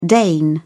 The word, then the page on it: dane: British English